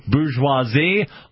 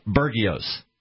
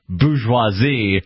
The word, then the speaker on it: bourgeoisie: The French word bourgeoisie looks very much like how an American would say Bergios. I didn't even know until probably the last couple of years that it was pronounced bourgeoisie.